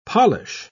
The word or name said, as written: polish